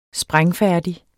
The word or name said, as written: sprængfærdig